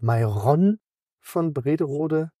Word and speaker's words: van Brederode: Wir haben sehr viel von Tino Polster jetzt gelernt diese Woche, wie die Spieler richtig ausgesprochen werden. Also Lündal und Mayron von Brederode und eben Arc. Giovanni Arc. So dass ich die ganzen Kaffee-Haag-Witze kann ich jetzt in die Tonne kloppen.